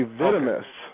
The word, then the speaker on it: Vitimus: Is actually Vitimus.